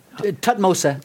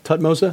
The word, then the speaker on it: Thutmose: Is it Thutmose? – Tutmosa. – Tutmosa? – Tutmosa. – Okay, trying to get my pronunciation. Mm-hmm. Speak like a Chicagoan. Thutmose. – Yeah.